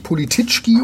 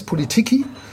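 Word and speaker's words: Politycki: Also nicht Polititschki oder so, sondern Matthias Politiki.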